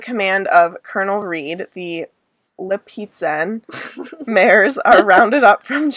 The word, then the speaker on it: Lipizzan: Lipizzan. Like pizza.